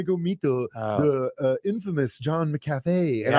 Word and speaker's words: McAfee: Is it McCafe or McAfee? – I always thought it to be McAfee, right? – John McAfee.